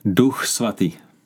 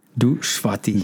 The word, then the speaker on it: Duch Svatý: Duch Svatý. – Duch Svatý.